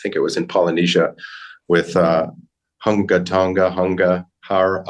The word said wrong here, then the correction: Ha'apai: ha Ha'apai.